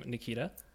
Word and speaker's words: Nikita: He goes by the name Nikita.